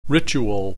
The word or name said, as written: ritual